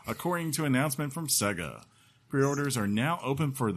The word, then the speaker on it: Sega: Sega and Atlus characters. I should have said it right. Sega.